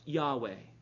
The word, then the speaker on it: Yahweh: It's YHWH. It's most likely pronounced Yahweh.